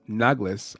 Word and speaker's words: Naglis: It's Naglis. I'm going to make sure I say that correctly.